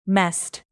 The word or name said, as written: messed